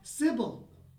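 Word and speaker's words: Cybele: Now it's spelled C-Y-B-E-L, but I'm assuming that's it's Sibyl. Yeah. It's Sibyl Faulty.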